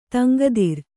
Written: ತಂಗದಿರ್

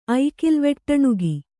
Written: ಐಕಿಲ್ವೆಟ್ಟಣುಗಿ

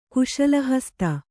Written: ಕುಶಲಹಸ್ತೆ

